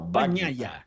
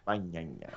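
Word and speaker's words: Bagnaia: Banhaia. Banhaia, isso aí.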